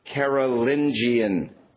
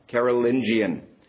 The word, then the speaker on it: Carolingian: And again, if you look at your chart, You see that word there, and that's how it's properly pronounced, Carolingian. Carolingian.